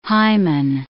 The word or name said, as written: hymen